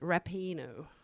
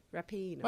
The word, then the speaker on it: Rapinoe: And also, I did pronounce Megan's name wrong. It is Megan Rapinoe. – Oh. – Rapinoe.